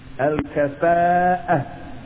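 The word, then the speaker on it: Al-kafa'ah (الكفاءة): Al-kafa'ah. And for those of you who want to spell it phonetically, it is A-L-K-A-F-A-A, F as in Frank, A-A, apostrophe, A-H, as in Harry, A-H as in Harry, Al-Kafa'ah.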